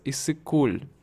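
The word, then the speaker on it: Иссык-Куль: А вот я слышал еще, что у вас есть озеро такое, называется Исыкуль.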